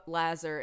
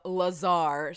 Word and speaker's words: Lazar: So it's not Bob Lazar, it's Bob Lazar.